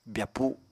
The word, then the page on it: byapu: apatani